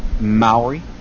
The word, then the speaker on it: Maori: And how did you say it? – Maori. – Maori.